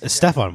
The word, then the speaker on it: Stephon: Stephen Marbury, right? – I think it's Stephon.